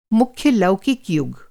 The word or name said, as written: लौकिक